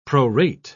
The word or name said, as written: prorate